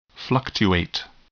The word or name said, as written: fluctuate